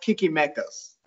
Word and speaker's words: Chichimecas: Well, my wife said it's probably pronounced Chichimecas. – Chichimecas. – Oh, see, I was right. I told her Chichi as well, and she said it's probably not right to say Kiki.